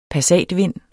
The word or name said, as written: passatvind